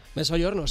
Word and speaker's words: Mezzogiorno: Rodrigo de Pablo, Mezzogiorno se dice, ¿no? – Mezzogiorno, sí. Mezzogiorno.